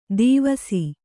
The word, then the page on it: ದೀವಸಿ: dīvasi